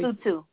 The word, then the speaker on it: Lesotho: You went to Lesotho. Am I pronouncing it correctly? – Lesotho. – Yeah, Lesotho. Mm-hmm.